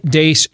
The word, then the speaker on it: Deace: Follow us on Twitter at Steve Dace Show. And that last name is spelled D-E-A-C-E for those of you listening today on Blaze Radio or later today on the podcast.